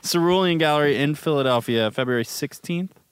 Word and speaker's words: Cerulean: which is going to be at Cerulean Gallery. Am I saying that right? – Yeah. Cerulean. Like the color.